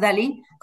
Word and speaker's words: DALL-E: Que s'escriu DALL-E, però és Dalí, com es pronuncia, i aleshores permet crear imatges que són realment impressionants, ¿vale?